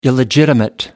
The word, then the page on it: illegitimate: American English